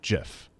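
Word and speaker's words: GIF: Please stop saying the hard G on Jif. It's pronounced like the peanut butter brand, Jif.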